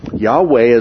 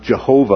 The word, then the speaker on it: Yahweh: Yahweh is the personal name of God. Sometimes that's pronounced Jehovah, although that's not the most correct way to pronounce it.